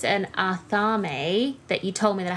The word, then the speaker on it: athame: So an Atharme, so it's spelled A-T-H-A-M-E for anyone that's wondering.